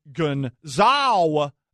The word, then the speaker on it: Gonzaga: I've always said Gonzaga correctly, but if the school is named after Aloysius Gon Gonzaga, that's how you're supposed to pronounce it. That's how it's pronounced in Italian.